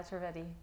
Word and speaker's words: Trivedi: Pooja Trivedi, that's how you say it, right? – Yeah, Trivedi.